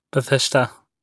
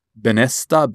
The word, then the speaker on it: Bethesda: My entire life, I would go like, Bethesda. I don't even know how I'll pronounce it, but it was always something like, Benesta, Bethesda.